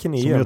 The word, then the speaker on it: Kineum: Är det Kineum som, som man säger? – Ja, jag vill väl minnas att det är så.